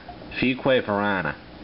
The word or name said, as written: Fuquay-Varina